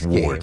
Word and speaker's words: sword: The next sword. Game. Nobody says the W I do. – Sword. – Every time, because that's how you say it. – Of course you do. – It's not sword.